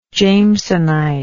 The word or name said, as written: jamesonite